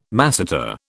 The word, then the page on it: masseter: British English